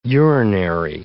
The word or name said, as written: urinary